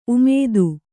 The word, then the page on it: ಉಮೇದ: umēda